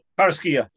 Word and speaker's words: Carrasquilla: But the number one offender arranging his junk time and time again was Carrasquilla. Did I say that right? – Carrasquilla. – Yeah, what he said.